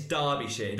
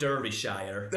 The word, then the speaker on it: Derbyshire: It's Derbyshire, not Derbyshire.